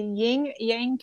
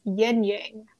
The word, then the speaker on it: Yin Yang: The serial killer was called the Ying Yang Killer, and his mark was the ancient Chinese symbol. The correct spelling and pronunciation, however, is Yin Yang.